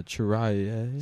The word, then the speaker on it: Cirelli: That was that video that Nick Cirelli. – How do you pronounce? I don't think it's Cirelli. – What? Cirelli. – Yeah, Chiraya. It's Italian. It's Chiraya. Chiraya.